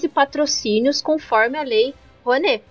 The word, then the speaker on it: Rouanet: que nesse mesmo ano houve um corte de, de dinheiro para a lei Juanu. é Rouanet ou Juanu? Eu tô falando certo? Rouanet. – Rouanet, é, certo? Isso.